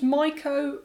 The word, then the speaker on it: Mycho: So yeah, for all the listeners, Mycho rhymes with psycho.